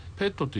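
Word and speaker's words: PET: でもう一つの機械は滝沢村にペットという機械がありまして、ペットという動物のペットと同じ発音するん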